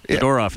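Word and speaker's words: Zadorov: Zadarov? Is that how you say it? – Second star tonight. Zadarov, yeah.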